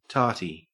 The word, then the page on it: tarty: Australian English